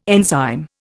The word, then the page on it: enzyme: Indian